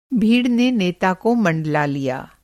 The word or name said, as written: मँडलाना